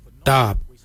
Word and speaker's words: dhaab: Today we will look at, for knife we say darb. Darb is spelled D-H-A-A-B. It is a traditional knife, a darb.